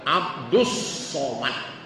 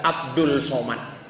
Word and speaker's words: Abdus Somad: Tapi mohon maaf nih Pak Yai, iki salah, karena itu bacanya Mustri Idram Syamsiah, Abdus Somad. Tapi karena dari SD sudah terlanjur salah, di ijazah Abdul Somad, maka kita lanjutkanlah kesalahan itu.